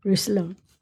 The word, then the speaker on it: Roussillon: R-O-U-S-S-I-L-L-O-N. – Roussillon. – The Languedoc Roussillon region in southern France. It literally means... – Let's say Roussillon. – Roussillon? – Roussillon.